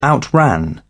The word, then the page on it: outran: British English